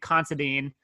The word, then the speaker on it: Considine: Patty, and I apologize because I know I'm going to butcher this last name, Considine. – I think he's, that's right.